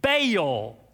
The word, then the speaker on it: Baal: And Israel at that time has been overrun by the worship of an idol, a false god named Baal, B-A-A-L.